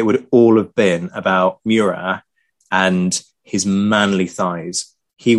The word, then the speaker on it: Murat: Or Marshal Murat, as we should call him, to do the French pronunciation.